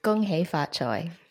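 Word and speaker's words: Gong Hei Fat Choy: Gong Xi Fa Chai, or in Cantonese, Gong Hei Fa Choi.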